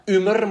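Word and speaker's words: Ymer: Makkelijk hij van uh, eh, Mikael ja, Umer, moet je het uitspreken eigenlijk. Die, eh, die Y in het Grieks, of in het, in het Zweeds.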